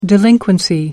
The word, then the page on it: delinquency: American English